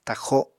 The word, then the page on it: taxo: apatani